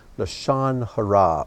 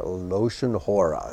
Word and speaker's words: Lashon Hara: And he's primarily remembered for his teachings on Lashon Hara, or Loshon Hora, as some pronounce it.